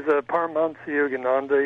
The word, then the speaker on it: Paramhansa Yogananda: His name is Paramhansa Yogananda.